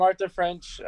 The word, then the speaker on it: Arthur French: So I'm Arthur French.